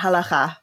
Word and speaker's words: halakha: You got to get that guttural cha in, but it's basically correct, halakha.